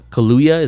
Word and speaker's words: Kaluuya: Kaluuya, is that how it is? – I would say so.